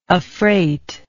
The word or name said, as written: affreightment